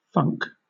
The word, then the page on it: funk: British English